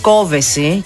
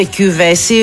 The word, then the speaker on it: Κόβεση: Είναι λέει Κόβεση και όχι ούτε Κιούβεση ούτε Κοβεσή. – Ναι. – Κόβεση λέει. Ναι. – Παιδιά, η ίδια το είπε. – Κόβεση. – Ναι, σίγουρα τονίζεται στο «ο».